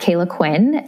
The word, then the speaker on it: Caila Quinn: I love to share and would love to get to know all of your listeners on my Instagram at Caila Quinn.